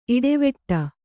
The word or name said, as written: ಇಡೆವೆಟ್ಟ